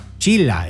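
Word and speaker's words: chila (χίλια): And it means 1000 is pronounced chila in Greek.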